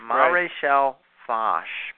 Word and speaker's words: Maréchal Foch: said it back then, but it turns out that it's Maréchal Foch. – Foch. – Maréchal Foch is how you're supposed to say it.